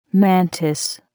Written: mantis

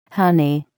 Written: honey

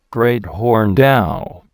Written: great horned owl

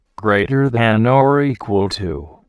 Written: greater than or equal to

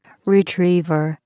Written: retriever

